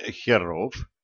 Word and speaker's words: херов: Неужто инициатива местных бывших товарищей, а ныне херов? Ударение сделал я на последнем слоге, демонстрируя свое отношение перекрасившимся.